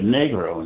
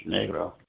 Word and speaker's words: negro: They pronounce it Negro in Spanish, Negro.